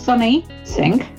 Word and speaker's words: Sunny Singh: It's Sunny Singh, and it's she and her.